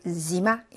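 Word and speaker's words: 지마 (зима): 겨울, 지마.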